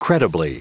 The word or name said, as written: credibly